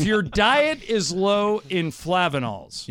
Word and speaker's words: flavanols: Is it flavanols? F L A V-A-N-O-L-S. – Sounds right.